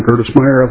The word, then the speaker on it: Gerdesmeier: Chairman Dabney, members of the committee, my name is Don Gertesmeyer, last name G-E-R-D-E-S-M-E-I-E-R.